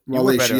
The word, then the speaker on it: Wallachia: of Wallachia. I can't pronounce it. – Wallachia, you yeah, you're right. Wallachia.